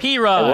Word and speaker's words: Perine: But Perine, like the all-time leading rusher in the history of Oklahoma football, Dennis.